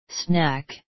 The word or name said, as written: snack